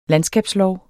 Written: LANDSKABSFORM

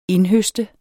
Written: indhøste

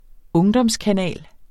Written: ungdomskanal

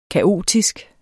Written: kaotisk

